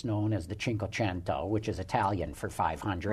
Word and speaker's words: Cinquecento: It is the Cinquecento. It's just we pronounce it 500 in the U.S.